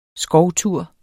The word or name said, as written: skovtur